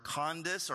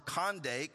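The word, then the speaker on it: Kandake: He was a court official, a treasurer, to be exact, of Candace or Condice or Candace, right? And that's multiple pronunciations because that's not actually a personal name. This is not a personal name, right? Candace or Condake was actually the name of a dynasty.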